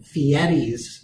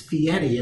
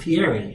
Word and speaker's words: Fieri: It is an R, but it's pronounced Fieri for some reason.